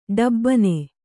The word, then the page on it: ಡಬಾಣ: ḍabāṇa